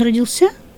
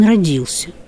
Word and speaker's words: родился: Вот у меня часто спрашивают, он родился в Новгороде или он родился? И я объясняю, да, что родился – это более старая норма.